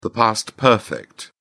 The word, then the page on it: past perfect: British English